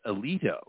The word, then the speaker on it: Aledo: With this episode is when the ship that they're on is right, the Aledo, right? A-L-E-D-O. – Yeah. – But I kept hearing it being pronounced Alito, right?